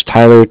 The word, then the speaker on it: Tyler: Yeah, if you could just put, my name is Tyler, T-Y-L-E-R, and I'm calling with Cardiff. It's spelled C-A-R-D-I-F-F, as in Frank.